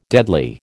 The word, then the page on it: deadly: American English